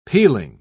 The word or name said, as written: peeling